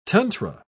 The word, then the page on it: tantra: British English